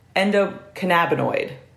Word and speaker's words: endocannabinoid: And it is endocannabinoid, not endocannabinoid, as I continuously call it throughout the episode.